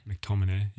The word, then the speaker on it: McTominay: McTominay, yeah.